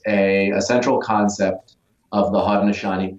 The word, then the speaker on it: Haudenosaunee: Haudenosaunee. – Yeah, Haudenosaunee.